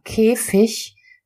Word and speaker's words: Käfig: Dann Wörter wie König oder Käfig, die ein IG am Ende haben, werden mit einem weichen CH ausgesprochen. Das heißt, selbst da, da hast du wieder das G am Ende, würdest du es so lesen, wie es dort steht, Käfig, Käfig, würdest du das G auch nicht hören, denn da ist wieder die Auslautverhärtung und du hörst ein K.